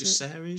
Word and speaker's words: Jacaerys: So we have obviously Joffrey Velaryon, uh, Lucerys Velaryon, and is it Jacaerys Velaryon? Is that how you pronounce Jacaerys, it? Jacaerys. yeah, that's the one.